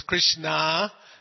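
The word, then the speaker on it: Kṛṣṇa: Now, in today's verse, the lesson here, Queen Draupadi, who we heard from a previous verse, is also known as Krishna. As I said, it's very important that those diacritics be pronounced properly, because you have K-R dot under, S dot under, N dot under, A, that's Krishna, the Supreme Personality of the Godhead.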